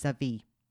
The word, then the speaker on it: Xavier: The French pronunciation is Xavier, not an H as I want to do, or Xavier as some. So we're thinking X-Men here, yeah.